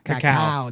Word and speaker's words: cacao: We call it cacao. That's the way, the proper way to say it. Cacao.